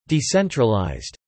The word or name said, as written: decentralised